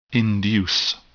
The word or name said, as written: induce